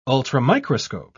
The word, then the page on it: ultramicroscope: British English